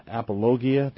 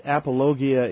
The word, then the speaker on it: apologia: I think it's the Greek word apologia.